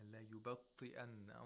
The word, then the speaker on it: لَيُبَطِّئَنَّ: Yeah, la yubatti'anna. And also the ta has a tashdeed and also a kasra.